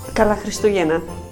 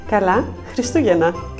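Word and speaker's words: Kala Christogena (Καλά Χριστούγεννα): Kala Christogena. – Say that again. – Kala Christogena.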